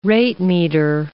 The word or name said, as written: ratemeter